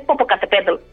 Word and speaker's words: Popocatépetl: Yo es que estoy acostumbrada ya a nombrarlo, porque claro, como está tan de moda últimamente, es Popocatépetl.